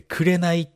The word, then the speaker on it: 紅: あ、じゃあそれだったら紅ってさ、紅っていう漢字ってね、もう一つ読み方があって、くれないっていう読み方があるわけですよ。